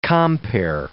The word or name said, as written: compere